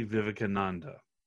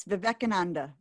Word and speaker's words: Vivekananda: Swami Vivekananda. – Yes, Vivekananda.